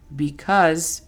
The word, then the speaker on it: because: Because significa por qué, pero es el que utilizamos para dar respuestas o decir oraciones que no son preguntas. La pronunciación fonética de esta es B-I-C-A-S. Because. Entonces, a ver, repasemos un poco.